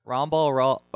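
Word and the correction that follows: Ramba Ral: Oh, Ramba Rawl. That's why. – Ramba Rawl. – Ramba Rawl. I've been saying his name wrong this whole time.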